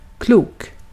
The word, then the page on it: klok: [kluːk]